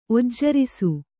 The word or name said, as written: ಒಜ್ಜರಿಸು